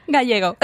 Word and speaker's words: Gallego: No, I didn't say it right. – Gallego.